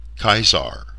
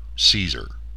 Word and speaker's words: Caesar: As well, we've tended toward the ancient Latin hard C, since they never had a soft C, Caesar, not Caesar, as most have heard the name.